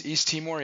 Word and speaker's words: Timor: was just one area I didn't even know about at the time it was going on, was that island in Indonesia, Timor, if I'm pronouncing it right, Timor. – Yeah, East Timor, yeah.